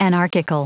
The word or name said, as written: anarchical